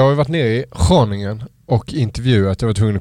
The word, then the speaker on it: Groningen: Det har liksom satt sig den uttalat. Det är inte många som säger Groningen, utan där är det Groningen ändå.